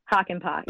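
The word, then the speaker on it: Hakanpää: That's how, but that's actually how you pronounce his last name, right? – Hakanpaa, yes. – Okay. – That is correct.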